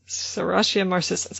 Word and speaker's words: Serratia marcescens: Serratia marcescens.